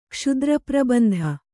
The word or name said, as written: ಕ್ಷುದ್ರಪ್ರಬಂಧ